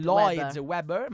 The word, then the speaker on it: Lloyd Webber: Lloyd Webber.